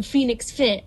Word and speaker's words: FNX Fit: I got it from Phoenix Fit. Like the bird? No, it's spelled FNX Fit.